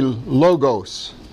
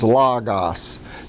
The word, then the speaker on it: logos: Most people say logos. But the actual pronunciation is logos. See, O is a different one. That's omega. That's a whole different letter.